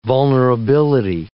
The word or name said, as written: vulnerability